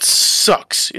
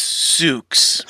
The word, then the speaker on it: Sooks: Rodney does not like to be called Sucks. It's Sooks.